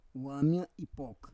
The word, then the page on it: wam ya ipok: Roon